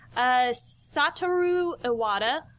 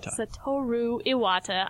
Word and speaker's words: Satoru Iwata: Satoru Iwata, as everyone knows. Are you going to correct me in my pronunciation? – I was about to, yeah. – Satoru Iwata. I know, I keep saying it wrong.